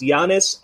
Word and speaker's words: Giannis: It's first of all, it's Giannis. You don't pronounce the G. It's Giannis Antetokounmpo, something like that.